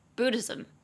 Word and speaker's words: Buddhism: 佛教也是把 s t 改成 s m，变成三个音节，念作 Buddhism, Buddhism。对，前面听起来很像那个 booty，前面听起来像是屁股的那个英文。